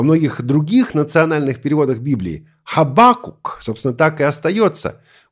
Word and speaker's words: Хабакук: Некоторые говорят Аввакум, другие говорят «Аввакум» с ударением на второй слог. Поэтому скажу пару слов об ударении. Вообще, строго говоря, произношение «Аввакум» более правильное. Кстати, если хотите уж совсем правильно, то вспомните, что в древнееврейском языке имя этого пророка оканчивалось на «к», а не на «м», то есть «Хабакук».